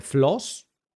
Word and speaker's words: FLOSS: Hay lo que se le llama software flows ¿vale? Que se dice así rápido, se lee rápido, que es f l o s -S.